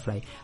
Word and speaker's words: Frey: le dicen Fry, la, pronunciación correcta es Fry.